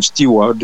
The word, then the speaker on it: Steward: Alors, le nom se prononce Steward. C'est comme celui qui travaille dans l'avion.